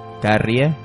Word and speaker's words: Carrie: Carrie, para los que no saben pronunciarlo.